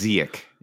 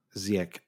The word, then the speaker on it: Ziyech: I think he's got the hard CH at the end. I could be wrong about this, but I think it's Ziyech. Yeah. – Ziyech.